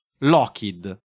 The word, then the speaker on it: Lockheed: John ha un cognome di origine scozzese che per gli americani è molto difficile pronunciare correttamente e per come è scritto lo storpiano in Laughhead, tanto che i suoi figli in seguito lo cambieranno perché ricalchi anche nella scrittura la sua pronuncia corretta, Lockheed.